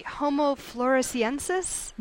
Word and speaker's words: Homo floresiensis: Let me see if I can say this correctly. Homo floresiensis? – You said it right.